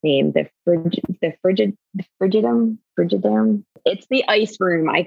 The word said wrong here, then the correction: Frigidarium: Frigidarium. That's it. Frigidarium.